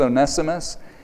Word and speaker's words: Onesimus: It looks like it's spelled Onesimus, but it's Onesimus.